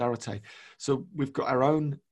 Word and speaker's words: Arete: And Arete, which is spelled A-R-E-T-E, but pronounced Arete,